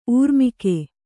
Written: ಊರ್ಮಿಕೆ

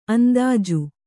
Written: ಅಂದೋಜಿ

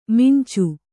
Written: ಮಂಚ

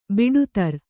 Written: ಬುಟೇದಾರಿ

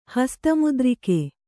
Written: ಹಸ್ತಮುದ್ರಿಕೆ

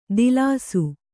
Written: ದಿಲಾಸು

